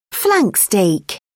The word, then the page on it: flank steak: British English